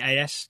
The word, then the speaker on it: AS: The AS newspaper? – Yes, yep.